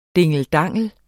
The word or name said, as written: dingeldangel